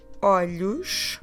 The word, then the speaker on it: olhos: First, the word for eyes is olhos.